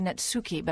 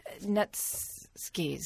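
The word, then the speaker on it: netsuke: And he and Edmond Duval inherited a collection of 264 I want to say netsuki, but that's not how you pronounce them. They're netskis, something like that.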